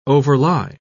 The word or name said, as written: overlie